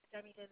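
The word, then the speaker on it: Ademidun: My first name is Demidun. That's A-D-E-M-I-D-U-N.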